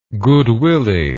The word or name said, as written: goodwilly